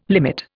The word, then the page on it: limit: Indian